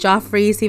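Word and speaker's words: Geoffrey's: Seems a little steep for me, considering it's a steak dinner, but this is Malibu, and the restaurant is pronounced Joffrey's, even though it's spelled Jeffrey, so, you know, we gotta understand.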